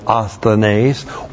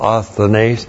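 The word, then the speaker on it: Asthenes (ἀσθενής): You would translate it for while we were still asthenes, or for when we were still asthenes. And now we have... Asthenes. A-S-T-H-E-N long E-S. Asthenes.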